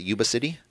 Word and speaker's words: Yuba City: That's how you pronounce it, Yuba City? – Yeah.